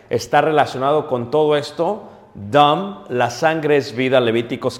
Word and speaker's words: dam: ¿Cómo se dice sangre en hebreo? Dam.